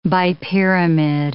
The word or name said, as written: bipyramid